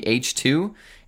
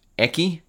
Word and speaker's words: H2: Today we're talking about the H2. It looks like it's pronounced Eki, but it's not. It's H2.